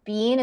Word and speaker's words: Biehn: Michael Biehl. He was also in Tombstone. – Michael Biehn.